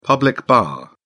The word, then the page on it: public bar: British English